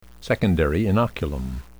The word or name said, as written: secondary inoculum